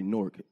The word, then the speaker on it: Newark: Oh, you're saying, it wrong. You got to say Newark. – N-O-R-K. – Newark. – Like y'all don't know how to pronounce it. No, because when y'all say Newark, it sounds like New York.